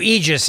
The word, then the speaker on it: Aegis: So Aegis is the correct way to pronounce it. Aegis.